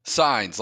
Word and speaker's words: Signs: Signs, like a street sign.